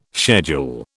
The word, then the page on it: schedule: British English